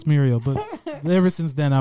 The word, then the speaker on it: Mirio: It's just the A is an I, so it's Mirio.